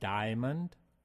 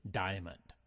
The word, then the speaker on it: diamond: One point is diamond can be pronounced diamond or diamond. It's your choice. Today we're going to use diamond.